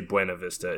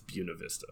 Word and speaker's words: Buena Vista: Don't try to say Buena Vista. It's Buena Vista.